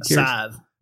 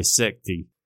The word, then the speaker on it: scythe: Scythe. – A scythe. I always want to say secty. – What? – Secty. – That's how it's spelled. S-C-Y-T-H-E. Right? – Okay.